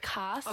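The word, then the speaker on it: castes: It's C-A-S-T-E-S. So like cast. – Oh, cast. – Casts. Okay. 3 years later, I found out how to pronounce it. Cast.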